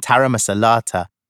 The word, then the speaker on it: taramasalata: There's olives, carrot sticks, pita bread and hummus, which I love, and taramasalata, which I think tastes yucky, but I love the word. I teach my friends how to pronounce it. Ta-ra-ma-sa-la-ta. Taramasalata.